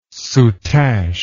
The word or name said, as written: soutache